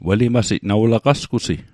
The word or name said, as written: welimasit